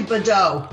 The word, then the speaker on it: Badeaux: You were very close. It's actually Badeaux.